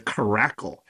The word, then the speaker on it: Caracle: da da da da da da da Crackle. And it's not, it sounds like crackle, but it's spelled caracle.